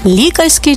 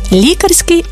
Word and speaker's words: лікарський: Лікарський чи лікарський? Можна вживати обидва варіанти, але в різних значеннях. Лікарський – це про лікаря та його діяльність, а лікарський – коли йдеться саме про ліки.